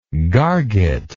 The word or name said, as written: garget